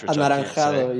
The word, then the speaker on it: anaranjado: Is that how you say it in Spanish? – Anaranjado, yeah. Or just...